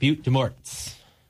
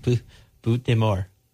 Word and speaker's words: Butte des Morts: And, you know, having taken Spanish, I see it's spelled B-U-T-T-E-D-E-S-M-O-R-T-S. – Yeah. – I called it Butte des Morts. – Butte des Morts. – It's Butte des Morts.